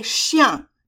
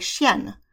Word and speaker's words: chien: So when you say in French, oh, I have a dog, you say chien in the masculine form. However, it ends in an N. And a lot of students, when they're reading it, like to pronounce that N and say chien, which is the feminine form of the word and means bitch.